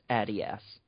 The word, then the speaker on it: Adidas: Thanks to, is it Adidas? Is that how it's pronounced? – It's Adidas. – Oh, Adidas. Okay.